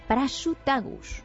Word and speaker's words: Prasutagus: con Prasutagus.